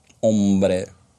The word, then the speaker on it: hombre: «Hombre» – это мужчина. Ну, как «hombre», но «h» не читается.